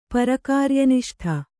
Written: ಪರಕಾರ್ಯನಿಷ್ಠ

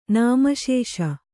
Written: ನಾಮಶೇಷ

